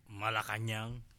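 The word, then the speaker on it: Malacañang: Malacanang. – Thank you. I got to go to Malacanang.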